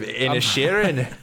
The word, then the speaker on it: Inisherin: I'm from the Banshees of Inisherin. Did I say it right time? Inisherin? – Yeah, you did. For the first time. Good job. Well done.